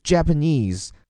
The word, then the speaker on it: Japanese: 首先，日本的 Japanese，注意它的发音哈，前面这个 J A 的字母 A 发作 a，另外整个单词的重音在后面 knees，所以是 Japanese。